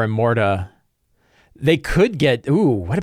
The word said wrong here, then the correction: Immortra: Immortra is her name. I've been saying it wrong.